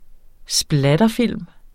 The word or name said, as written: splatterfilm